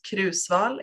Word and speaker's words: Kruusval: So I'm Johanna and my last name is pronounced Kruusval in Swedish.